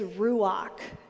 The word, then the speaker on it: ruach: I was taught the Hebrew word for this, and it's ruach. I struggle every time because I'm terrible at pronunciation. Ruach, R-U-A-C-H.